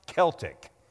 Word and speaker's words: Celtic: So the first thing that I want to say to you today is that it's pronounced Celtic, not like the Boston Celtics, which, by the way, are ranked sixth in the Eastern Conference, which is better than the Nuggets this year.